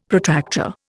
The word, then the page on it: Protractor: Indian